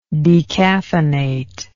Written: decaffeinate

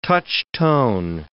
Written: touch-tone

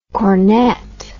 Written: cornett

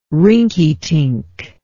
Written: rinkytink

